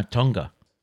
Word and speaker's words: Tonga: Val M says Tonga. It is where the day begins. I hope I pronounced that right. – Okay. T-U-N-G-A. – Cool. Yeah. – Yeah. Tonga.